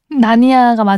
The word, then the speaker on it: 나니아: 사실 나디아 연대기는 끝까지 안 봐가지고. 저도 안 봤어. 네. 알폰소 쿠아라 감독의 영출. 나디아 아니에요? 나니아요? – 나니아가 맞을 거예요.